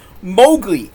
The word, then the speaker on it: Mowgli: It's Mowgli.